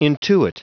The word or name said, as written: intuit